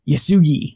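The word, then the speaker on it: Yesugei: Yasugi, Because I'm looking at it as three different syllables. There's yes, and then that special U, and then gi. So, right. Yasugi.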